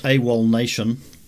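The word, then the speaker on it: AWOLNATION: I'm going to assume this is pronounced AWOL Nation. – Correct.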